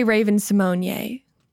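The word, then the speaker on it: Raven-Symoné: And I did say Raven Simonyi. That's the correct pronunciation.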